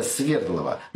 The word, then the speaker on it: Свердлова: Так вот, лично я считаю, что в убийстве царской семьи решающая роль именно Свердлова. Или, как иногда ставят... ударение Свердлова.